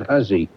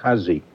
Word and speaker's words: Hussey: He pronounced it Hussey. I think she does too, Ruth Hussey.